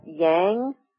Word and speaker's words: yang: is that yang rhymes with clang, so it's the noisier of the two.